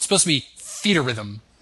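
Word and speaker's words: Theatrhythm: I thought this was Threat Rhythm, and then I'd heard someone pronounce it Feet Rhythm, which is actually how you'd think it was pronounced, but then I heard someone from Square pronounce it Theater Rhythm. It's supposed to be like Theater and Rhythm put into one word.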